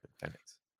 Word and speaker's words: Fenix: I was, you know, so this is circled on the poster for me, Mox against Fenix. – Now there were, thank you for correctly pronouncing his name.